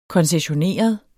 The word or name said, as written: koncessioneret